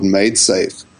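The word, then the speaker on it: MaidSafe: There's a whole project called MadeSafe, which is spelled Made, M-A-I-D, safe.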